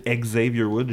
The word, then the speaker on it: Xavier Woods: Euh, c'est ça, lui, c'est Xavier Woods. Puis qu'il faut prononcer Xavier Woods, j'ai dit ça.